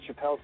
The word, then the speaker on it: Chappell: Oh, you said Chappell, correct? – Yes, okay, yeah.